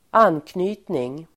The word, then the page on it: anknytning: [²'an:kny:tning]